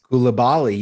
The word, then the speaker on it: Koulibaly: They've just signed Koulibaly. How do you say his name again? – Koulibaly.